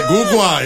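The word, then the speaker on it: Ogugua: So, Jalen Brunson, Carl, Ogugwa, you know what I'm saying? Did I say that right? – Ogugwa, yes.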